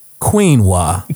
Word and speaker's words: quinoa: That how you say it? That's how you say it, right? – Quinoa? I don't know. We don't know. – Oh, it's quinoa.